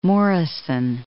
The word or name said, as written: Morison